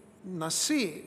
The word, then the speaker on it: nasi: The English word prince here is actually the Hebrew word nasi, N-A-S-I, with the emphasis on the I, nasi.